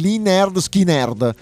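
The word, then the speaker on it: Lynyrd Skynyrd: E parte su una base che richiama il brano Tuesday Gone dell'album Pronounced Lead Nerd Skid Nerd dei Lee Nerd Skinnerd.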